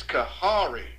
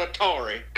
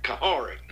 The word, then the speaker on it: Kahari: It's Kahari is how you pronounce it. I told you, Coach Tisdale come through in the clutch for me. He knew what I was talking about, Coach. Kahari not Atari, Kahari.